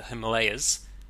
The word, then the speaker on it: Himalaya: And, It's about the Kingdom of Bhutan, which is a landlocked country in southern Asia, at the eastern end of the Himalayas, or Himalaya, as we've already discussed it, should be pronounced.